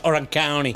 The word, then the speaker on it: Orange County: Che pronuncia Orange County. – Orange County. – Non è Orange County, come diresti tu, capito? E la differenza è tutta lì. – Perché? Io avrei detto country.